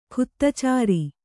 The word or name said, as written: ಖುತ್ತಚಾರಿ